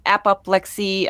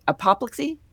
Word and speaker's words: apoplexy: The doctor says it was apoplexy. I know I pronounced that weird, but apoplexy. – That's the one. – Apoplexy. There we go.